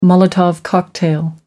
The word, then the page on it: molotov cocktail: American English